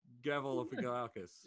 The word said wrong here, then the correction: Galifianakis: Yeah, Zach Galifianakis. – Really butchered that, though, didn't I? Really butchered it.